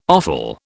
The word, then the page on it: offal: British English